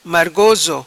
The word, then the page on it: Margoso: Patua